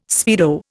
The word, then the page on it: speedo: Indian